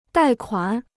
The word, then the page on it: 贷款: dài kuǎn